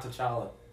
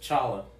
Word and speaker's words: T'Challa: In the comics, it's not T'Challa. It's just Chala. Because you don't pronounce catch. Catch. – Oh, that's right. The T is silent, actually.